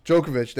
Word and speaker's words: Djokovic: Djokovic, there you go.